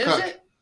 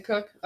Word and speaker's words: Koch: Ed Cook. That's how you pronounce it. It's weird. It's Cook. – Koch? Cook? – It's Cook. – Ed Cook. Okay. – It's Cook. I promise you. I have a friend whose name is spelled like that, and it's Cook.